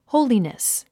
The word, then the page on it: holiness: hóulinəs; ホウリネス